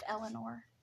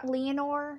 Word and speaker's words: Leonor: But Princess, I think her name is pronounced Eleanor. – Leonor.